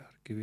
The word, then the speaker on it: Harkiv: Harkiv on siis Ukraina pärane? – Harkiv, jah.